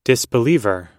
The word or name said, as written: disbeliever